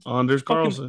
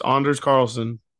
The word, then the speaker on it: Anders Carlson: Do you know how they pronounce his name? – Both kickers. Anders? – Yeah, Anders, dude. – Anders Carlson. – Fucking Anders, man. – It's Anders Carlson.